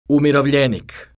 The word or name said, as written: umirovljenik